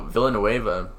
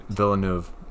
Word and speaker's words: Villeneuve: Although Villanueva didn't get Best Director nom, What movie did he direct? – Villeneuve.